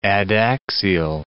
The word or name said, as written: adaxial